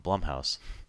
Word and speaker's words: Blumhouse: Blumhouse. Rhymes with plum.